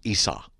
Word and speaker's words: Isa: Oh, what they're saying is Isa.